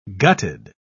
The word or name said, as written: gutted